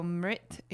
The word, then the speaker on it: Mrit: So Mrit is spelled M-R-T or M-R-I-T, Mrit, and then Yorma Amritam Gamaya.